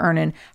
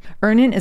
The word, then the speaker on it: Earnin: That's where Earnin has you covered. So all you need to do is go to either Google Play or the Apple App Store and download Earnin today. It's spelled E-A-R-N-I-N.